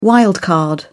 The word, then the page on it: wild card: British English